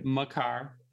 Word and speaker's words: Makar: First line they have on here is Chychrun and Cale Makar. Makar? I don't think I've ever said it right. – Makar.